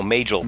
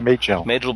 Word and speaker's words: Majel: It's played by Majel Barrett Roddenberry. You can't get better than that. You can't do better than that. – Major Barrett Roddenberry? – No, Majel. Majel Barrett Roddenberry, Gene Roddenberry's wife.